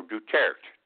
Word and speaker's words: Duterte: His name is Rodrigo uh, Duterte, D-U-T-E-R-T-E, Duterte, No, Duterte, Duterte. I apologize.